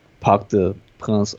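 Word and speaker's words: Parc des Princes: So you would try to get a close, narrow result at the Westfalenstadion and then bank on Dortmund's poor away form at the Parc de Prince, or however you pronounce it. – No, you nailed it.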